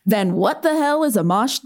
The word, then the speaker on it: Amash: And I'm using it to ask you, Hayes, what's a Justin Amash? – Okay, first of all, props for getting his name right. A lot of people say Amash, and he is a congressman from Michigan.